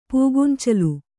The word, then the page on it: ಪೂಗೊಂಚಲು: Kannada